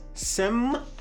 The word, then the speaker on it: senpai: Technically, it's pronounced senpai.